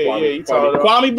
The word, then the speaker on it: Kwame: Yeah, Kwame Brown.